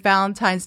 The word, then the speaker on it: Valentine's: So we're coming to you today with Valentine's Day. Today is actually... – Oh, she said it right. Valentine's. I'm watching your mouth.